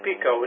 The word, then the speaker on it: Pico: Uitspraak is ook inderdaad Pico, niet Pico. Pico.